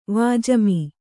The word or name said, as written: ವಾಜಿಮಿ